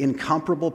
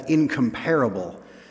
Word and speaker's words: incomparable: uh, I guess the correct pronunciation is, is incomparable, but a lot of times in our English language, English can be a little bit of a different language. We were talking about this in the office this week. We think of that as incomparable, but the, the, the emphasis should be on the other syllable. So it's really one of those words you want to make sure you have the right emphasis on the right syllable.